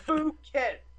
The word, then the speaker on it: Phuket: UFOs drop into Phuket. That's the name of the town. – Close enough. – Phuket.